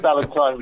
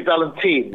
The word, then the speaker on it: Valentine: You said Valentine, which is right. Some people say Valentine, which is wrong.